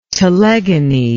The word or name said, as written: telegony